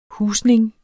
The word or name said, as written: husning